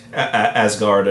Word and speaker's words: Asgard: How do you pronounce that? – Asgard is an acceptable pronunciation.